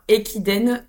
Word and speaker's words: Ekiden: Alors comment ça s'écrit, Riken? C'est R. – Non, Ekiden, Equiden, pardon.